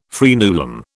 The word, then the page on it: fraenulum: British English